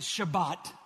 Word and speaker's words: Shabbat: We've learned that word in the Hebrew, you pronounce it Shabbat.